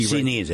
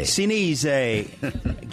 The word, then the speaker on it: Sinise: We'll be Sinise. – Right. Sinise.